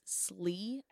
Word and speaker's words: Slee: But I want to say it's Slee, S-L-E-E.